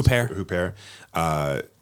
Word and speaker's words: Huppert: Huppert.